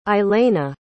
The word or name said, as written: aleyna